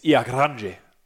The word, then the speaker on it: Ilha Grande: We went to a place called Ilha Grande. Ilha Grande. It's for you know, guys that can't really understand how Portuguese is written and therefore pronounced. It's Ilha Grande. But in Portuguese, it's Ilha Grande.